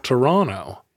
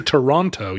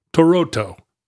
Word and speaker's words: Toronto: Do they pronounce the second T in Toronto? – Toronto. – You get a lot of trouble for that If you don't pronounce it. – If you do pronounce, say Toronto, you might as well be saying blorb blorb blorb.